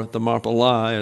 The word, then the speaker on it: Thermopylae: It has to do with the battle for Thermopylae, or Thermopylae, as some pronounce it.